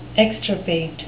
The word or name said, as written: extirpate